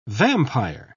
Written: vampire

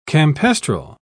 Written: campestral

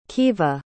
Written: keva

